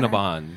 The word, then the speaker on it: Cinnabon: It's Cinnabon, right? Even though it's spelled bon. Yeah, like, who is anybody calling it a bon? Are you? Is that how you say it? – Cinnabon.